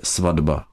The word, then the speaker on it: svatba: Ve slově svatba se uplatňuje takzvaná spodoba znělosti. Stojí tu vedle sebe neznělá souhláska T a po ní znělá souhláska B, a prosled hlásek tohoto typu platí, že se artikulačně T přizpůsobí následující znělé souhlásce a vysloví se zde D. Takže spisovná výslovnost je svatba.